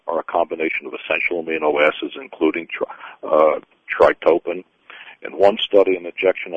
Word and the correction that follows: tryptophan: Spell it, because it doesn't sound right. – T-Y-R-P-T-O-P-H-A-N. – Tryptophan. – Tryptophan. – Oh, there must be tryptophan they're saying, but go ahead, I'm sorry.